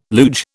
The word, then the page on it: luge: British English